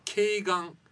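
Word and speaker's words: 鶏眼: ただ専門用語では、医療用語では頸眼、鶏の目と書いて頸眼と呼ぶ。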